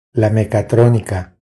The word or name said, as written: La mecatronica